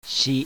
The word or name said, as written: SI